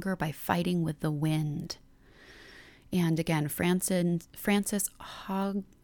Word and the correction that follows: Hodgson: oh my gosh, can I say her name? Frances Hogson Burnett is who wrote The Secret Garden, but that quote, "She made herself stronger by fighting the wind with the wind."